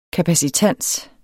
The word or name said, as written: kapacitans